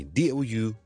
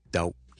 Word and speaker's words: doubt: 即管同大家讲一讲，最常见到我哋成日都讲 silent letter 嘅例子咧，就系我哋怀疑 doubt，好怀疑一件事，中间个 b 咧，我哋系唔需要读出嚟，所以我哋唔读成 doubt。 Doubt 当是好像 out 的 out 一个 d 在前面就是个 doubt、 okay?